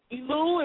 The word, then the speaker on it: Elul: Elul, if I'm pronouncing that right.